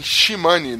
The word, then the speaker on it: Shimane: Família participa de cremação de corpo errado após equívoco de funerária em Shimane. Não sei como é que fala, Shimali, sei lá. Shimali, é. é. Shimane.